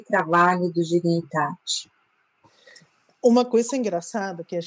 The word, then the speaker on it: dignitate: E eu fiquei feliz porque a pronúncia se parece mais o italiano. Então não é tão difícil para mim, né, que é mais perto. Então, dignitate. Mas a gente pronuncia como pode, não tem problema.